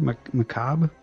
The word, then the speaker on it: macabre: Is that how you pronounce that word? Macabre? – Macabre. The R-E is silent.